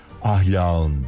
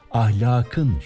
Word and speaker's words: ahlakın: Ahlağın değil, ahlakın şeklinde yazılıp okunuyor.